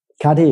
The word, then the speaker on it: Cuddy: Cuddy, ja.